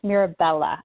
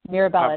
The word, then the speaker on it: Mirabella: Just one slight correction, it's Mirabella. I know a lot of people on Anchor are like, how do you say your last name? It's Mirabella, it's Italian.